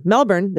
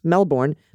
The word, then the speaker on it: Melbourne: Melbourne, the town in Australia, is not pronounced Melbourne, but Melbourne. Oh, Melbourne. There's not even an er.